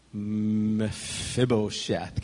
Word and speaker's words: Mephibosheth: Mephibosheth.